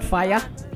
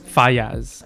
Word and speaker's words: Faiyaz: Brent Faiyaz. – Faiyaz. – Faiyaz. – Yeah, it's spelled in a pretty weird way. – F-I-Y-A-Z. – Close. Brent F-A-I-Y-A-Z.